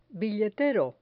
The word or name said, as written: billetero